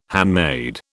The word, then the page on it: handmaid: British English